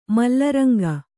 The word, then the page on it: ಮಲ್ಲರಂಗ: malla ranga